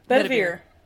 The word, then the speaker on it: Bedivere: Okay, so once again, adding to the Queen's Knights, what is this pronunciation? Sir Bevery? What? – Oh, Bevedere? – Bedivere? – Bedivere.